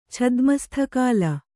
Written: ಛದ್ಮಸ್ಥಕಾಲ

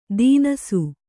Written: ತಿನಸು

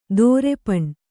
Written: ದರ್ಪಣ

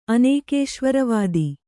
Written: ಅನೇಕೇಶ್ವರವಾದಿ

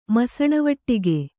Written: ಮಸಣವಾಡಗೆ

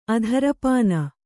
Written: ಅಧರಪಾನ

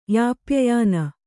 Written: ಯಾಪ್ಯಯಾನ

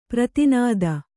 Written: ಪ್ರಧಾನಿತಿ

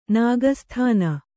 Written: ನಾಗಸ್ಥಾನ

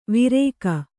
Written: ವಿರೇಕ

